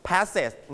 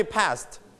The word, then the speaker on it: passed: นะครับ So when you put ed, you don't pronounce pass it. No. No pass it. No kiss it. No. No miss it. No. นะครับเราจะไม่ออกเสียงว่าเสร็จนะครับแบบนั้นนะครับเราจะบอกว่าเสียงเธอนะครับ Pass เธอ Repeat after me. Passed.